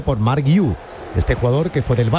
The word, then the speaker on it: Guiu: No, ese, este es Guiu, Guiu catalán y el otro un Nahashi. – Vale. – Este Nahashi, es Guiu, se escribe.